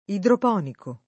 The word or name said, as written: idroponico